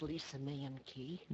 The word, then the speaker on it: Lisa Mankey: It's pronounced Lisa Mankey. – Mankey, thank you for the correction. – It's okay, you pronounce it the way it's supposed to be, but it's been Americanized.